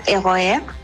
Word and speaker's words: RER: Si tú tomas un tren, los trenes son como metro tren, se llaman RER, que se pronuncia ROER.